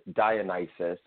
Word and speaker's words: Dionysus: Dionysus? Did I say that right? – Pronunciation is a matter of perception, but nevertheless, it's Dionysus, and I'm okay with Dionysus.